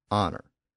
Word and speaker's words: honor: The loss of the H sound was quite common. We see it in the modern pronunciation of words like hour, H O U R, and honor. The H sound in those words has long since disappeared, but the spelling still reflects the original pronunciation.